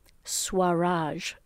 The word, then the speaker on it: Swaraj: So there is a university that is surprisingly affordable in India, and it's called Swaraj, and I'll spell it for you. S as in Shannon, W-A-R-A-J, Swaraj University in India.